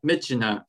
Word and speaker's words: Myitkyina: Uh, Myitkyina, I think that's the correct pronunciation.